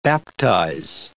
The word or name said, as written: baptise